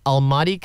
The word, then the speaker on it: Almaty: I do apologize if I mispronounced this, from Almaty, Kazakhstan. – Yes, it's right, yeah.